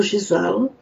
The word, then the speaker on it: Giselle: Já jsem teď na to taky myslela, že vlastně po francouzské výslovnosti je to Giselle.